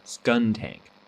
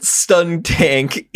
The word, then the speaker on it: Skuntank: Stuntank. – Skuntank. It's a skunk.